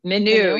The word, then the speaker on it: Minho: Caldo Verde, meaning green broth, comes from the northern region of Portugal, Minho. Is that right? – Minho.